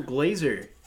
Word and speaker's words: Glaser: Today we have Kyle Glaser. Or is it Glasser, Glaser? Should have asked beforehand. – It's Glaser, spelled with an S, pronounced like a Z.